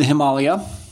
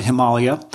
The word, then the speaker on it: Himalaya: So if you are in Nepal, you would call it Himalaya. In the United States, we have this way of using the vowel A in two different pronunciations. So we'd say Himalaya.